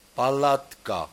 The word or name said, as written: Palatka